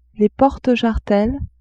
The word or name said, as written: porte-jarretelles